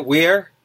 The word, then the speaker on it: Weir: Is that how you pronounce it? Weir? – Yeah, that's right. – W-E-I-R,